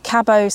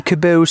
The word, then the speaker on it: Cabot: Now, I pronounce it Cabot Circus. Well, you pronounce it French for some reason. – I don't know why. As soon as I see that word, C-A-B-O-T, right, I want to say Cabot Circus.